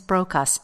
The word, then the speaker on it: Brocas: Go to the little search box, and you can just put in Joanne, J-O-A-N-N-E. Last name is Brocas.